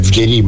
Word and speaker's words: Vieri: Peraltro, caro Cecca, anche il buon Francesco Benvenuti ci scrive che si pronuncia Vieri, quindi non so, oramai diciamo che mi fido molto, quindi...